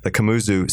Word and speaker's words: Kamuzu: Talk about your work at what KCH is, which is the Kamuzu Central Hospital, correct? – Yeah.